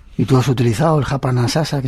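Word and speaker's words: hapa na sasa: que se llama Japanasasa. ¿Se dice así? – Así es, Japanasasa, que quiere decir aquí y ahora en lengua Kiswahili, que es un idioma vehicular del África Oriental.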